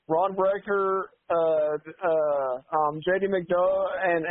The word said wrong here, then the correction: Bron Breakker: Bron Breakker.